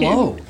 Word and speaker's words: Mauna Loa: That's got to be the pronunciation. It's Mauna Loa.